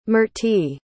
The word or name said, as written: mertie